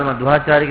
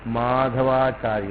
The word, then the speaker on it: मध्वाचार्य: श्रीपाद मध्वाचार्य के बारे में चर्चा करने से पहले ये बात को ध्यान में रखना चाहिए, उनका जो नाम है माधवा, माधवाचार्य नहीं मध्वाचार्य है। तो लोग इनका नाम उच्चारण करते हैं MADHVA मध्वा, MA माधवानी, MADA माधवा, मध्वाचार्य, मधु से माधवाचार्य बना, इसलिए उनका नाम है मध्वाचार्य।